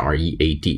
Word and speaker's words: read: 过去时呢，就是还是 r e a d，只不过发音发 read。